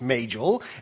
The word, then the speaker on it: Majel: And it says it's pronounced Majel. And according to another site, which was talking about it, the like genealogy of names, they actually say that the name comes from an African name and is pronounced M-A-Y-G-E-L, Majel. So, according to all of these, they say it's Majel. She's actually said it's Majel.